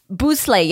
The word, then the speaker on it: Bustle: I think it's pronounced Boussle, yeah. – Boussle, mm-hmm.